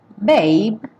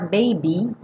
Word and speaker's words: babe: Babe, sem pronunciar o E. E cuidado para não falar baby, porque essa é outra palavra, uma variação.